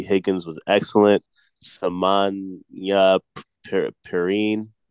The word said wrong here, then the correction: Samaje Perine: how do you say his name? He was excellent. – Samaje Perine. – Yeah, Perine.